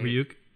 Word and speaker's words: Ryuk: He meets Ryuk. Is that how you say it? Right? Ryuk. Ryuk. Yeah. Ryuk.